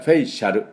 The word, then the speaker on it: facial: まあそういうことで、このそういえばこの Facial ですね、 Facial、 A をほとんど発音しません。これも半母音か、ま、全く発音しなくてもいいぐらいです。で、フェイショー、フェイシャ、フェイシャルと言いますね。フェイショーですね。こう、要注意です。